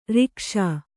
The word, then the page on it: ರೂಕ್ಷ: rūkṣa